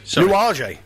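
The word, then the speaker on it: Nuages: Oh, yeah, so Tony Wilson has it's New Ages... Is a Django Reinhardt track, so there you go. You'll have to. – Nuage. – Nuage. – Okay. – Yeah, well, I didn't know it's spelled pronounced Nuage.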